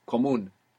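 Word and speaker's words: común: Is it comuno? Comono. – Comun. – Ah. – So there's no way to know that. – Comuno or comun? – Comun. – Comun. – Comun with the accent on the end.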